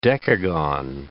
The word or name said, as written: decagon